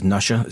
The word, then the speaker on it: Nussia: Find them on MySpace as Nusha. It's spelt N-U-S-S-I-A. It's like Russia with an N instead of an R.